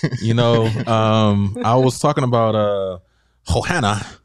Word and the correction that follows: Joanna: I know I said that wrong, Joanna, because I don't want people.